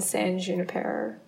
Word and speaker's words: San Junipero: Yeah, you know, all the good stuff that they were playing, San Junipero. Is that how you say it? – I think it is, yeah.